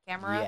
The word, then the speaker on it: Camerupt: But the Camerupt, am I saying that correctly? Right? Camerupt? – Yes.